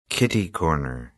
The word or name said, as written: kitty-corner